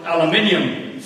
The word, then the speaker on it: aluminium: One group got aluminium fluoride, correct pronunciation, and the other got sodium fluoride.